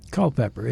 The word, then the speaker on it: Culpeper: Culpeper. It's cull with pepper.